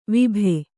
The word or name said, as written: ವಿಭೆ